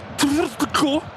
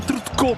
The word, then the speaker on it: Tvrtko: Est-ce que c'est Stefan Trutko, TVRTKO, le premier, ouais, Stefan Trutko,